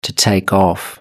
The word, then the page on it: to take off: British English